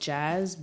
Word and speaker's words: Jxzz: So my name is pronounced Jazz, but it's spelled JXZZ.